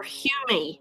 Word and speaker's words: Hume: And it's by, now his name is spelled H-U-M-E. Would you pronounce that Hume or Hume? What do you think? – Hume. – Hume Nisbet. N-I-S-B-E-T.